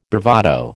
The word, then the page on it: bravado: American English